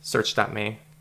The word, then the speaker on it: Searx: Um, I saw different pronunciations. The the X can be pronounced with like a ch sound in certain languages, so it's just searx.me. I, I don't know. Just whatever makes like happy. A, like a Kai character in Greek or something too.